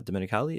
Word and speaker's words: Domenicali: And I just saw a headline: uh, Stefano Dominic uh, Domenicali.